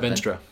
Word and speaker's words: Venstre: Venstre. – Venstre, Danish People's Party, Social Democrats.